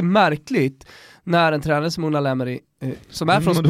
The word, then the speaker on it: Unai Emery: Du måste lära dig att han heter Unai Emery. – Det är olika det där, beroende på spanska uttal. I Galicien, södra Galicien, där säger man Unai.